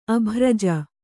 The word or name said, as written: ಅಭ್ರಜ